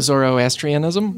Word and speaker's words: Zoroastrianism: Oh, Zoroastrianism? – Yes.